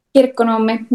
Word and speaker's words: Kirkkonummi: Kirkkonummi, Finland. Did I say that right? – Kirkkonummi, yeah. – I was close.